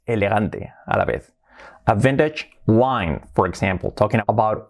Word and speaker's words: vintage: Vintage, incluso la T la hacemos más suave, vintage, vintage, vintage.